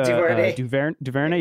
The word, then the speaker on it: DuVernay: Ava DuVernay.